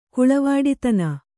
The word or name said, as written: ಕುಳವಾಡಿತನ